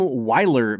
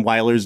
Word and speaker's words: Wyler: William Wyler's name is, I mean, if you just pronounce him as V's, you've pretty much got it.